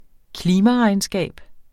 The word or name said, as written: klimaregnskab